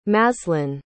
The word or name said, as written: maslin